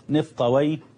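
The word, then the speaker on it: نفطويه: أهل اللغة ينطقونها كما أنطقها الآن، سيباوي باباوي نفطاوي بفتح الواو وإسكان الياء، وأهل الحديث لا ينطقونها كذلك، إنما ينطقونها يفرون من نطق وي، لأنهم يرون حديثا ضعيفا لا يثبت إسناده بأن وي اسم شيطان، ولذلك وحتى وإن ضعف الحديث فإن بعض أهل العلم أو كثير منهم صراحة يحتاط يعني، ولذلك ينطقونها على خلاف ذلك، فيقولون سيبويا وبابويا ونفطوية وحموية وهكذا، فرارا من أن يقولوا ويه يعني.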